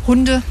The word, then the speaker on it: Hundekind: Aber Betonung liegt auf Hundekind.